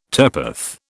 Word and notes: turpeth: British English

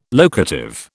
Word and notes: locative: British English

